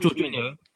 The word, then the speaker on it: Jorginho: What I will say about Jorginho, I feel like, and I've got the pronunciation right this time, but I feel like Jorginho is really good, like underrated defensively.